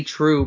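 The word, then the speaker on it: Trieu: Lady Trio? How would you say that? – It's like Lady True, basically, I think is how you say it.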